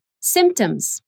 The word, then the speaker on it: symptoms: The P is very subtle, but we need to pronounce it. Say it again symptoms.